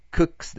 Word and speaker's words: cooks: cooks だよね。 cooks. ね。 cook だから、これも works と同じでしょ。ね。 cooks って読むよね。